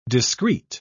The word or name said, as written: discrete